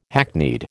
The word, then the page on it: hackneyed: American English